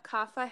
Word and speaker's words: Kapha: Kapha has, it's spelled K-A-P-H-A. So it's, but it's, yeah, Kapha.